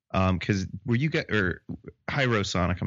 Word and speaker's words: Hyro Sonic: So how did Hero Sonic come about? Hyro Sonic, I'm sorry, I'm saying it wrong.